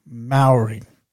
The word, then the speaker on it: Mowery: His name is Tyler Maori. Not Maori as in the Maori people, but Maori. It's spelled Mowery. So that's Mary Oscar Whiskey, Echo, Romeo, Yankee. So Maori. And he's pretty young, but he's pretty wise for his age too.